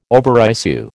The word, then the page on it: overissue: American English